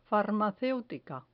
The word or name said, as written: farmaceutica